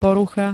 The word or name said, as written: porucha